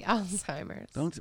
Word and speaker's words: Alzheimer's: She's a linguistics professor, and her family find the bonds tested when she is diagnosed with Alzheimer's disease. – That would be Alzheimer's.